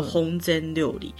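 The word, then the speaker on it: 本膳料理: 本善料理日文念作烘煎料理。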